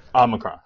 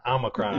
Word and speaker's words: Omicron: Omicron. Omicron.